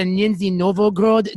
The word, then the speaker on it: Nizhny Novgorod: My favorite KHL jersey is the Nizhny Novgorod. Tell me if I'm pronouncing it right, because you know, is it? I like the Nizhny Novgorod jerseys with that stag on it.